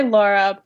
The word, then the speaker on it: Laura: It's Laura, L-A-U. I know some people pronounce it Laura, Laura. How do you pronounce your name? Prefer to pronounce your name? – I say Laura, but Laura, I think it's literally just where you're from in the country.